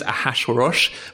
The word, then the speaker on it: Ahasuerus: Apparently, it is Ahasuerus, but I can't say it.